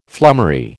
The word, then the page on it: flummery: American English